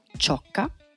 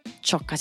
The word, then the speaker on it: Ciocca: Allora, soy mi amo Bianca Ciocca. Ciocca si scrive con C-I-O-C-C-A.